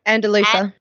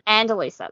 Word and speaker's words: Andalusia: With night fast approaching, the expedition stopped at a local village in the wine-producing region of, I don't know how to say it. – Andalusia. – Andalusia. There you go.